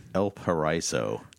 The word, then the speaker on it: Paraiso: El Paraiso. – Okay. – P-A-R-A-I-S-O.